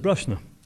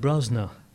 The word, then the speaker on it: Brosna: And, and I'll, I'll always know a person that's not from Brusna, because I, I pronounce it Brusna, And an outsider will call it Brosna. – Yeah. Immediately, there you go. And the older generation would, would, have, would have called it Breshna.